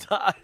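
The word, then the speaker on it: Tai: You know his name's Ty, right? – Ty. Am I saying it wrong? Tia? Ty? Oh, it's just Ty.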